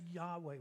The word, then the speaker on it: Yahweh: Say Yahweh when you see it. Y A H W E H. That's how you'd spell it.